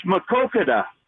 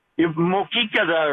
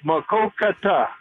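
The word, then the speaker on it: Maquoketa: It's Makokada. – What did I say? – Mokikada or some. I tried to pronounce it for you phonetically, but it's Makokata.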